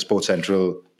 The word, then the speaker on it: SportCentrAll: Like when you go to, so it's spelled Sports Central, like A L L, but pronounced Sports Central, as Philip said.